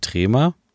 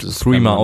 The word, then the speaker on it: Threema: Ist es nicht Trema? Aber es wird Threema ausgesprochen. TH. Okay. Also TH. Ich habe das, ich hab das immer Trema gesprochen.